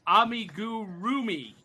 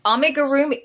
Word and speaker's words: amigurumi: And Cindy says that's pronounced Amigurumi. – Amigurumi.